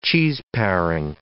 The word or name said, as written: cheeseparing